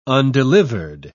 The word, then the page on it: undelivered: British English